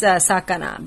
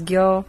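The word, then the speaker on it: sakana (魚): Now, mostly it's sakana, but it also has other pronunciations such as gyo, uo, and that's about it.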